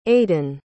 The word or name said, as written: aidenn